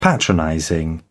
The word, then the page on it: patronizing: British English